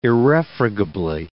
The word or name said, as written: irrefragably